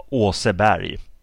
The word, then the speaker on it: Aase Berg: The the the AA thing. That's a Danish spelling of uh, the pronunciation Å Åseberg.